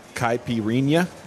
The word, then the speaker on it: Caipirinha: Caipirinha.